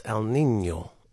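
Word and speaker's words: El Niño: Joy lamented my pronunciation of El Nino, because that's literally what I said. And that's probably what most people in the world say, El Nino. She said it's El Nino.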